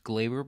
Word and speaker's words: Gleyber: Well, first of all, I think it's pronounced Gleyber because John Sterling, whenever he hits a home run, calls it Gleyber Day. So I think it's pronounced Gleyber, but that's besides the point.